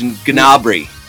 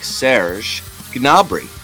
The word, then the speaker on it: Gnabry: The English, every English person just calls him Serge Gnabry. – Right. Okay. Well, how do the Germans say it? – They say it more like Serge Gnabry. – Oh, okay. – Gnabry. They do pronounce the G.